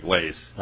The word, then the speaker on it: Lace: So, Chair Lilly and members of the committee, my name is Greg Lace. – Lace. – Just like L-A-C-E is how it's pronounced.